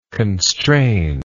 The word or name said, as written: constrainingly